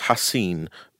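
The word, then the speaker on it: Ha Ha Clinton-Dix: But people, unsurprisingly, kept getting his name wrong because it's pronounced... – As you've just demonstrated. – Well, apparently it's pronounced Hasin, but...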